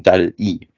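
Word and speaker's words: DALL-E: Nur das Dal-I, was du meinst, das klingt nur so und da ist natürlich das E drin, also I, das E englisch ausgesprochen und deswegen Dal-I.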